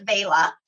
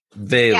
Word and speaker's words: Vela: Am I saying that right? Vila? – No. Well, you can say it any way that you want. I will say it's Vela. We pronounce it Vela, and it sounds better when our Italian team says it.